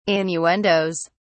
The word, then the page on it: innuendoes: British English